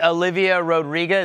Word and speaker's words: Olivia Rodrigo: Do you know that the Olivia Rodrigo, is that how you say her name? – Yeah.